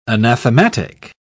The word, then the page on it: anathematic: British English